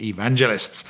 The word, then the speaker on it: evangelists: Of course, like all the sort of moral majority were and all the TV evangelicists. How do you say that? TV evangelists were.